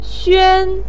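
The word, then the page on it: xuan: tone 1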